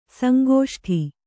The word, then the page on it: ಸಂಕಷ್ಟ: Kannada